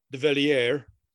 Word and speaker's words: Devillier: And just as important is Ron, I hope I get his name right, D-E-V-I-L-L-I-E-R, Develier. You know, if it's French, it'd be Develier, but I'm gonna go with Develier.